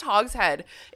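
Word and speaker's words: hogshead: It's pronounced hog's head.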